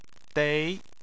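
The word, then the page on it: dei: tone 2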